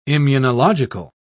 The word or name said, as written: immunological